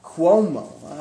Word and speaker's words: Cuomo: Cuomo.